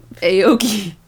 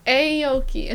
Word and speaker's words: Aoki: Aoki. – Aoki. It's because I keep go ah, and then I'm like, ah, okay.